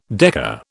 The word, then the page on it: decker: British English